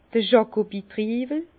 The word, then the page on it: d'r Jakowitriiwel: Bas-Rhin; Herrlisheim